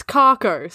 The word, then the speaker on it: Kharcho: But it's Carco. So instead of Clark, it's Carco. – How do you spell that? – K-H-A-R-C-H-O.